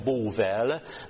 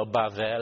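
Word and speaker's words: Bóvel (בבל): Ugye a Askenász kiejtése Bóvel, a Szefár kiejtéssel a Bável lenne,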